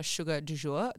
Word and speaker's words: Sugar du Joure: All my socials are Sugar du Jour. That is spelled S-U-G-A-R space D-U space J-O-U-R-E.